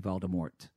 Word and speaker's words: Voldemort: if it was French, you wouldn't pronounce the T unless there was an E after the, right. It would be Voldemort. – Oh, because in the UK we say the T, but because when I do the American accent, all my T's are D's. – Ah. – Is one of the things that you do. – Yeah, J.K. Rowling, though, actually finally came out on Twitter and she said the T is not pronounced.